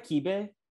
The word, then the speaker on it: kibbeh: It's spelled K-I-B-B-E-H, I think. – Oh, I did. Maybe. I didn't know that's how you pronounce it.